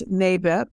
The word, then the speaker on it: NABIP: And it will be pronounced, the acronym is NABIP. Not NABIP, not N-A-B-I-P. It's everyone will say at the same as time, NABIP.